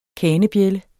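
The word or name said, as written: KANEBJÆLDER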